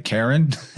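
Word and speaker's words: Qarin: And then there's in Islam a being called the Quarin, or I think you would pronounce it kind of like Karen.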